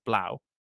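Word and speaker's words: 3LAU: It's spelled 3 Lau, L-A-U, but I've always said it like that. But I think it's actually pronounced Blau.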